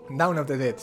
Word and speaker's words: Dawn of the Dead: Pues vamos a comentar sobre una de las pelis que hemos visto, que es Dawn of the Dead. ¿Lo he dicho bien? – Dawn of the Dead, sí, del 2004.